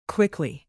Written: quickly

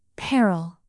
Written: peril

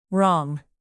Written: wrong

